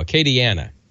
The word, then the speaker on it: Acadiana: Acadiana. There's no R in Acadiana.